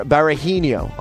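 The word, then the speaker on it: Berahino: So I've got Mourinho, Aguero, Barahino on the bench. That's how they say it in England. I don't know why. They don't pronounce the H's, that's why.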